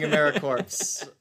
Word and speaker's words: AmeriCorps: So that's AmeriCorps, pronounced AmeriCorps, though. Yeah. That's the first hurdle when you become a member. Not saying AmeriCorps.